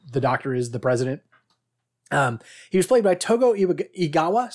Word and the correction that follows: Togo Igawa: Sorry, I tried to throw an extra syllable in there. Togo Igawa.